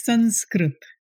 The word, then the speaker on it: Sanskrit: But the actual pronunciation is Sanskrit.